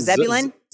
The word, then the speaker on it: Zebulun: It was the sixth stone, the stone that stands for the tribe of Zebulun. Is that how it's pronounced? – I believe so. Zebulun.